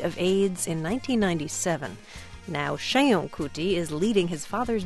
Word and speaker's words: Seun: Sheun. – Sheun. – Yes, good enough for me. – I'm going to go for better than good enough by the end of this interview. – Sheun is spelled S-E-U-N.